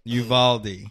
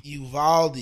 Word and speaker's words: Uvalde: Uvalde. – Uvalde. You had the Uvalde shooting.